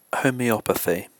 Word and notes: homeopathy: British English

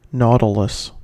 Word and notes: nautilus: American English

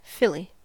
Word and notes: filly: American English

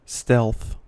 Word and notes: stealth: American English

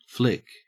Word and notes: flic: Australian English

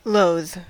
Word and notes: loathe: American English

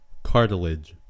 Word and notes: cartilage: American English

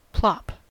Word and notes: plop: American English

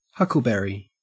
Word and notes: huckleberry: Australian English

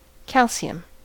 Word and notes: calcium: American English

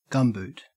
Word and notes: gumboot: Australian English

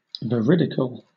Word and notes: veridical: British English

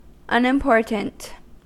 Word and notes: unimportant: American English

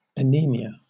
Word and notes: anemia: British English

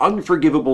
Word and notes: unforgivable: American English

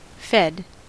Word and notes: fed: American English

